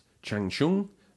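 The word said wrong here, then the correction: Shang Tsung: and Nightwolf are out now. So if Mortal Kombat fans have no idea what I'm talking about, it's probably because I pronounced it wrongly. – Shang-Chung. – Shang-Tsung. You're having a go.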